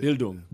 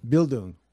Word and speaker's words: Bildung: It's the Bildung. – Bildung.